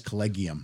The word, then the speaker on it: Collegium: Oh, and we're doing a hard G, Global Jazz Collegium.